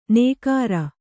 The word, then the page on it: ನೇಗಾರ: kannada